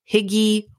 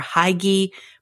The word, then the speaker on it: Hygge: Now, Hygge is spelled H-Y-G-G-E. I always thought it was pronounced Hygge or Hygge, but it actually is pronounced Hygge.